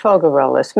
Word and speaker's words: Faugerolas: My name is Faugerolas, just F-A-U-G-E-R-O-L-A-S. – Faugerolas, Marie-Ange Faugerolas.